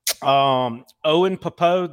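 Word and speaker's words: Pappoe: And real quick with Auburn, Owen Popo, the I think I pronounced his last name Sounds right.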